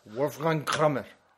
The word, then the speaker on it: Wolfgang Kramer: O como se dice que mi pronunciación es horrorosa. – Wolfgang Kramer. – Muy bien, gracias. Es, alemán.